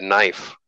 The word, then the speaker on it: knife: Who, I was talking to somebody, but we got into the word knife. How Knife. – the word knife with the silent K,